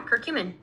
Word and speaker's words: curcumin: Is that how you say it? Am I saying it right? – Yeah, curcumin. – Curcumin. Yep. Okay, so walk us through how once you started teaching as a gym teacher and how you came to this whole curcumin thing.